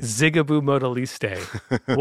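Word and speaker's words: Zigaboo Modeliste: But it's definitely Zigaboo Modaliste, who's my favorite in my top three drummers. I just put the fact you pronounced that correctly. – Zigaboo Modaliste.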